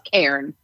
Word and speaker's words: cairn: Despite many people pronouncing this like the name Karen, like I just did. – Know, the pros know to use one syllable instead of two, so it's Karen.